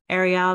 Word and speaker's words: Ariel: Ariel, I'm based out in Tampa, Florida.